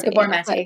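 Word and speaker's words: Gabor Maté: Do you follow Gabor Mate? – Yes. I love him. – I don't know if I'm saying his name correctly, but I think that's how you are. Gabor Mate.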